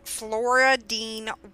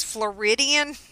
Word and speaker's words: Floradean: And for some reason, I keep wanting to say her name as Floridian. Floridian. I guess just that's what my eye sees. so if I mess it up, that's why. But it's Flora Dean.